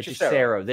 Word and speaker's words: Hechicero: Rex Fortune says, what are the chance I see my dream match of Claudio versus, I can't pronounce, I always have trouble, Hechicero. My Hechicero. God. Hechicero. There you go.